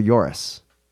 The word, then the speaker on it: Joris: J-O-R-I-S. Joris or Joris? – What do you feel? – Nailed it. – What do you think? – Yeah. Joris. – Okay. – Joris, thank you so much for the email.